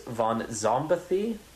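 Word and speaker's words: Von Szombathy: My guest today is Chris Von Zombathy.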